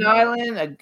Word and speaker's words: Chaman Island: How do you pronounce it? Chaman, Shaman Island? – Chaman Island, a Texas ghost story.